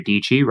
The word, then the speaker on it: Dietschy: Another one who's really good, and she does this more from, I think, like branding perspective, but Sarah Dietschy, rhymes with peachy.